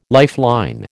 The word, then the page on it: lifeline: American English